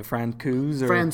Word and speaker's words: Francouz: Francouz.